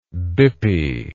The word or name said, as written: bippy